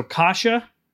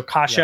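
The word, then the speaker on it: cassia: So kasha cinnamon, it's kind of like kashi, but it's in pronunciation, but not an E at the end, an A. So kasha, yeah. It's C-A-S-S-I-A. And then Ceylon cinnamon, C-E-Y-L-O-N.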